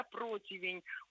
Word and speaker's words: противень: противень, ударение у нас падает на первый слог, но вот эту букву «и», вот этот второй слог мы не выкидываем, и мы его... помним о нем и произносим тоже.